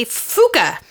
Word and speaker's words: phooka: Well, the first one that I picked is a puka or a fuka. It's spelled P-H-O-O-K-A, like phone.